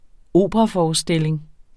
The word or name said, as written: operaforestilling